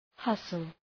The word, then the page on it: hustle: {‘hʌsəl}